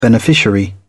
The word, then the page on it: beneficiary: British English